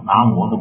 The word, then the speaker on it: 南无: 那个这个我说一个稍微好听点的话啊，就是咱们大家都知道，一般现在咱们念这个南无阿弥陀佛，这个是有无的无，念的是摩，南无的摩。这样的南无这个词是唐朝的时候的读音叫摩。但是现在呢，咱们北方人呢一般读呢是读无，这个时过境迁，这个是谁都没办法的事。